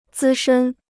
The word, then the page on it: 资深: zī shēn